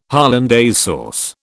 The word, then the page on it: hollandaise sauce: British English